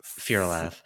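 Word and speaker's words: Fyr'alath: Fear-a-laugh. Fear-a-laugh. It's the opposite. Like Fyrak is Fyrak. This is Fear-a-laugh.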